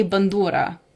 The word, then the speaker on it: bandura: Yeah, so in Ukrainian you say bandura. – Bandura.